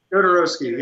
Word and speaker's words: Jodorowsky: Jodorowsky. – Jodorowsky, yeah.